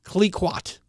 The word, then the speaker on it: Clicquot: V-E-U-V-E. You pronounce V-E-U-V-E. Come on, French speakers. – Veuve. – Clicquot.